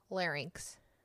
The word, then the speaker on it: larynx: Larynx? – Larynx.